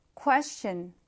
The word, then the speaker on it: question: 강세를 강하게 앞에 두고요. question. 이렇게 발음을 해주시면 됩니다. 여러분, 사실 이 question에서 quest라는 표현이 무언가 답을 구하고 찾는다 라는 의미가 있어요.